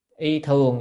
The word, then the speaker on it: y thường: Phục dĩ tứ thiên, à, tứ bát vạn tứ thiên y thưởng, y thường nhé, chữ này đọc là thường, tức là lại dùng mà 84.000 cái gì y phục, cái chữ thường này đó, chữ thường này chính là cái quần.